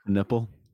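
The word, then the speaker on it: Kon Knueppel: Con Knipple. Is that how you pronounce his name? – Con Knipple.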